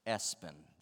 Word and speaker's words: ESPN: I saw an article a few years ago about parents who had named their kids Espen. That's spelled ESPN, by the way.